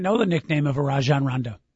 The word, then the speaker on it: Rajon: Rajon Rondo, excuse me. That's how you're supposed to pronounce it.